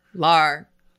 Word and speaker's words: Lahr: My guest today is Billy Lair. Did I pronounce that right? – Lar. It's Billy Lahr. – Lar.